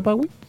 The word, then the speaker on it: Pawwy: Y Pawi se escribe. Ah, esa parte es importante. Sí, con dos W. Sí, con dos W. Y con Y al final. – ¿No es cierto? Sí. Ya, perfecto. P-A-W-W-Y. Sí, perfecto.